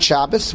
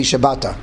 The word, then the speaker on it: Shabbata: And now, not Shabbos, but maybe Shabbata is the correct way to pronounce it.